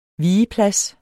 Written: vigeplads

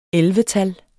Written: ellevetal

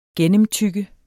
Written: gennemtygge

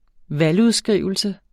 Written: valgudskrivelse

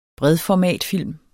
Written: bredformatfilm

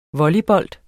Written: volleybold

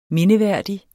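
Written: mindeværdig

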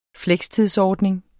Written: flekstidsordning

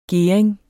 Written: gering

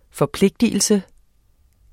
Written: forpligtelse

